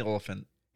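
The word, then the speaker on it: Olyphant: But and even the other guy, how do you say his name? Oliphant? – Timothy Oliphant.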